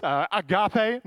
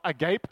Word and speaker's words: agape: Philia, but no, it's agape, not agape.